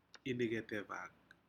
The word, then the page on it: incetevak: Aneityum